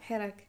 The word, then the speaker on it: Hirak: Hirak.